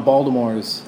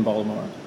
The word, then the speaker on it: Baltimore: I like how you made it with a D. – That's how they say it in Baltimore. If you say Baltimore, it immediately outs you as an outsider.